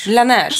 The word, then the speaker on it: Laneige: För den här otroliga ögonbalmet, som, eller säga ögon, läppbalsamet som vi fick testa, vad heter från Lanché? – Laneige. Som ingen kunde uttala.